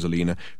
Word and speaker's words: Alina: The old bat's name was Alina, pronounced to rhyme with cleaner, which was an irony that Dirk now no longer relished, into opening the fridge door.